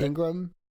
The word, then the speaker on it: Laura Ingraham: and then Fox host Laura Ingraham, am I pronouncing her name right? – That's Ingram, I think. – Yep. – Ingram.